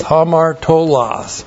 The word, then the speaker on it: hamartolos: Looks like that in the Greek, H-A-M-A-R-T, long O-L-O-S, hamartolos.